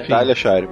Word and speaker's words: Talia Shire: Temos também a que também é uma das atrizes favoritas do Jurassic Cast pela sua atuação no Rock, Talia Shire. Acho que é assim que pronuncia, né? – Talia China, ou Talia Shire. – É Talia Shire mesmo.